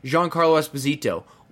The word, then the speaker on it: Giancarlo Esposito: I mean, it's literally um, Giancarlo Esposito. Is is it Jean? I always butcher this. – Yeah, yeah, yeah, you got it. Right. Yeah, Giancarlo Esposito.